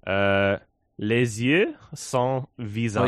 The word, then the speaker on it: Les yeux sans visage: Les yeux sans visage. – Oh, you actually say the S. – Les yeux. – Les yeux? – I mean, yeah, in some situations you would pronounce the S.